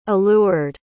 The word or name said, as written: allured